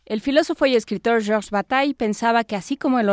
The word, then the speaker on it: Bataille: Qué bonito pronunciaste Bataille, el nombre de Bataille, que a tantos nos cuesta tanto trabajo. Qué belleza.